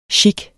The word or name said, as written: chik